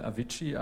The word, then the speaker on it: Avicii: wie zum Beispiel Avicii, ein DJ. – Die beiden I am Ende spricht man als eines.